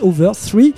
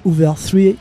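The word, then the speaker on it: Hoover III: Et quel groupe, c'était les Hoover 3, ça s'écrit avec 3 i à la fin, on dit Hoover 3. J'ai déjà passé ça de nombreuses fois dans Stone Circus, allez, 5 fois j'ai regardé. Anthony, t'as pas écouté? – Et ben voilà. – Il dormait. Donc ils ont, je reprends mes petites notes, parce que j'ai pris des notes, et oui. Ils sont de Los Angeles, Californie, ça se prononce, je l'ai dit, Hoover 3, comme le chiffre 3, premier album en 2014, 6 autres suivront.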